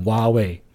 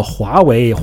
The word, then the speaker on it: 华为: 就像你说华为手机在美国，华为这个品牌，老美在电视上讲它的时候都说哇为，对吧？就把 H 这个音给省略掉了，或者是变成了一个 W 这个音哇为。那你想跟他争论说这个东西叫做华为，华为，华为，老外能懂吗？